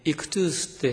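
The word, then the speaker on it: イ ク トゥ ース (ΙΧΘΥΣ): 5文字でね、イクトゥースって発音するそうですけども、で、この 5 文字は、ある言葉の、まあ、頭文字なんですね。うん、イエス、キリスト、神の子、救い主。その頭文字並べるとイクトゥースになる。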